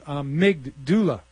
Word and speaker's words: amygdala: Okay, amygdala is pronounced amygdala.